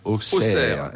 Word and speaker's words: Auxerre: Ah, oh, ça s'écrit Auxerre et ça se prononce Auxerre. – Auxerre. Auxerre. Et, euh, les, Bourguignons ont toujours eu un problème avec les X.